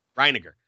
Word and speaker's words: Reiniger: I'm going to say this wrong. Was it Reiniger? – Reiniger.